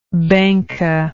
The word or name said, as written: Bangka